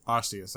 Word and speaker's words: Arceus: How do you say this Pokemon's name? Is it Arceus? – Arceus, I believe.